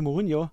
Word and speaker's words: Mourinho: Hinweis 3 wäre gewesen, 2009 kam es zu einem Leihgeschäft mit Inter Mailand, der damalige Trainer José Mourinho.